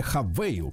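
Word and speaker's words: Haval: У Хавал, то есть компания Хавейл, конечно. Пишется Хавал, но читать, читать надо как надо.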